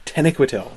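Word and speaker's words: Taniquetil: Tenequetil, which is clearly how it is pronounced, is that is how Christopher Tolkien pronounces it in the recordings we have of him reading bits of the Silmarillion.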